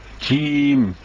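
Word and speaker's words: Cim (جيم): Adı cimdir. Cim. C okunmaz. Cim diye okunur.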